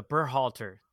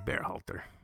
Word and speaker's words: Berhalter: The era of the Berhalter. Did I say that right? – Yeah, Greg Berhalter. You got it.